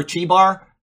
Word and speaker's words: Chebar: It's like when you see in the book of Daniel, the river Chebar, there is no Chi. It's a Ch, all right? The C-H anywhere in the Bible is always a Ch sound.